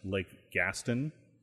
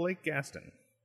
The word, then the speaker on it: Gaston: And then second half of the week, I was back in the town, which apparently is pronounced Lake Gaston. – Okay. – It's spelled like the guy from Beauty and the Beast, Gaston, which is how I was saying it until I got there this last time. The guy was like, "Welcome to Lake Gaston." I'm like, "Oh, of course. Of course it's not pronounced the French way."